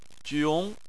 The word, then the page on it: jiong: 2